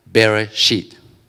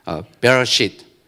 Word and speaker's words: Bereshit: We don't know about these Hebrew letters, but it's actually pronounced Bereshit. Now, be careful how you do use this word. Okay. Bereshit.